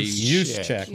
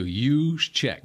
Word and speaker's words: Juszczyk: Juszczyk, I get it. – Juszczyk. – No J in there. – Forget the J. It's okay. – Juszczyk. – Like a Y-O-U. Juszczyk.